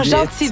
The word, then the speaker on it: Jeanty: Ah, Gentil, donc on dit ça. – Ouais, s'écrit J-E-A-N-T-Y.